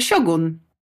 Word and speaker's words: siogun: A teraz uwaga, pierwsza rzecz, którą musimy sobie powiedzieć, to, że prawidłowa wymowa tytułu tego serialu to nie Szogun, tylko Siogun. – Naprawdę? O kurde. – Tak? Tak, ponieważ w języku polskim słowo japońskie wymawia się właśnie siogun, a ten zapis szogun przez SZ to jest przepisany z angielskiej transkrypcji tego słowa.